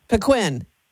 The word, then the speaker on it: Paquin: Paquin. – Yes, ma'am. That's the correct pronunciation.